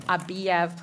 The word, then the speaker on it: Abiev: Our second case is case number A24-1669, State of Minnesota versus Abiev. Please correct me if I've pronounced that incorrectly. Feel free to approach and get comfortable. – That is correct, Your Honor, and that is the correct pronunciation.